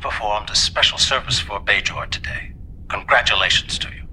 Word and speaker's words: Bajor: She has the hard J. Bajor.